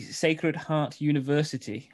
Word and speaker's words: Sacred Heart University: And you go to let me just bring it up here for you, the Sacred Heart University. Am I saying that right? Is that how you'd say that? – Yeah, that's right.